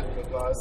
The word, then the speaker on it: La Paz: You as a kid, like, going back to you as a kid in La Paz, like, did I say that right? – Yeah. – La Paz, like, is, you know, your dad was already a racer, so you were already into motorcycles and that kind of stuff.